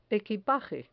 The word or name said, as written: equipaje